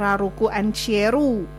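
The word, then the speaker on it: L'Arc~en~Ciel: Jadi Larkensil kalau di kanji Jepangnya tuh bacanya Raruku Anchieru.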